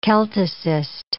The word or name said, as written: Celticist